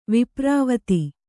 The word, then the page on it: ವಿಪ್ರಾವತಿ: Kannada